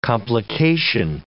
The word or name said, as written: complication